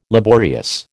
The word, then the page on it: laborious: American English